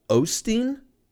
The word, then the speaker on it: Osteen: Anywho's, Your Best Life Now by Joel Os- Os- Osteen. Olstein. I thought it was Olstein, like O L, like like Gary Oldman. Like I thought it was Ol. It's Joel Osteen.